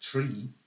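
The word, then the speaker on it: tree (три): The word for 3 in Russian, pronounced tree, sounds very similar to the word pronounced gri, which means now.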